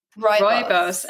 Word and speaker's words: Rooibos: Rooibos.